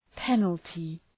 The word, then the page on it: penalty: ‘penəltı